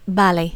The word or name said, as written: vale